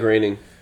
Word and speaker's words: Groening: Matt Groening.